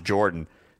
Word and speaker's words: Yordan: And yes, he spells it Y-O-R-D-A-N, but it's pronounced Jordan.